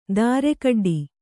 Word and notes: ದಾರೆಕಡ್ಡಿ: dāre kaḍḍi

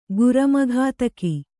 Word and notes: ಗುರಮಘಾತಕ: guramaghātaka

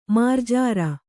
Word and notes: ಮಾರ್ಜಾರ: mārjāra

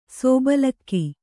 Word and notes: ಸೋಬಲಕ್ಕಿ: sōbalakki

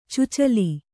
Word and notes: ಚುಚಲಿ: cucali

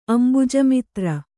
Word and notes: ಅಂಭೋಜಮಿತ್ರ: ambhōjamitra

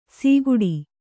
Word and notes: ಸೀಗುಡಿ: sīguḍi

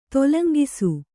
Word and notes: ತೊಲಂಗಿಸು: Kannada

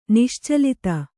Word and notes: ನಿಶ್ಚಲತೆ: niścalate

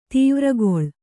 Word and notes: ದ್ವಾರಗೊಲ್ಲ: dvāra golla

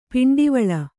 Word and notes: ಪಿಂಡಿವಳ: kannada

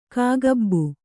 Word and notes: ಕಾಕಬ್ಬು: kāka bbu

